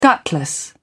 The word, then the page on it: gutless: British English